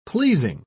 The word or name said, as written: pleasing